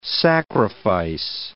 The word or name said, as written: sacrifice